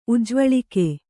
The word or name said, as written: ಉಜ್ವಳಿಕೆ